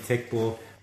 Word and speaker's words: Teqball: I think everyone out there would now be interested in tech ball. And tech ball is spelled TEQBALL, is that correct? – TEQBALL.